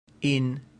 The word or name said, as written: in